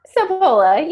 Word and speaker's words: Sapola: I should have asked you, how do you pronounce that? Sapola or Sapola? – Sapola, you got it.